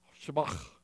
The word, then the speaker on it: Shabbat: To say that right, you got to say it kind of like you got a kernel of corn stuck in the back of your throat. Shabbat.